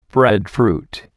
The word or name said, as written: breadfruit